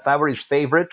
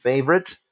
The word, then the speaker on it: favorite: And the pronunciation of the adjective favorite. No, it's not favorite. It's favorite. So there you go.